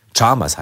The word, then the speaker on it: Chalmers: auch völlig falsch ausgesprochen, wie du mir erzählt hast, im Hörspiel ganz anders. Chalmers. Chalmers heißt es ja eigentlich.